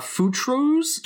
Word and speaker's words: Futtrus: Please pronounce that word for me again. – Futrus. – I think it's futrus. – Futrus. – It's I thought futrus. – For those of you playing along at home, it's spelled F-U-T-T-R-U-S.